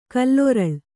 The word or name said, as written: ಕಾಲರಳಿ